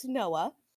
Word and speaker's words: Neauxah: He spells his name Neauxah, but it's pronounced Noah.